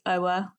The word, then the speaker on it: Oa: So my name is Oa.